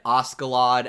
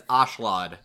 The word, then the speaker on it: Askeladd: I read Askeladd as it was read when it is actually more apt and said throughout this dub as Ashlad. – Yeah, I thought it was Askeladd too, and then it said Ashlad. I'm like, oh, that okay. The K is silent,